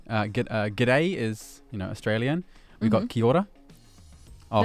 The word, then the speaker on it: Kia ora: It's kia ora. It's a separate word. Two separate words.